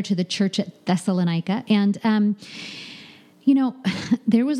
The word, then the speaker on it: Thessalonica: Thessalonica. Did I say that right? – Thessalonica? – Thessalonica. There you go.